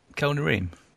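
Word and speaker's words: Kel'no'reem: Now, according to the transcript, that's spelled Kel, K-E-L, apostrophe no, apostrophe R-E-E-M. – Kel Nareem. It'll do.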